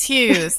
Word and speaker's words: Hughes: Yes, Hughes.